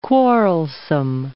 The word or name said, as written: quarrelsome